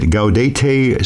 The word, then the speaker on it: Gaudete: We are in, am I going to pronounce this right, Gaudete Sunday. – You got it.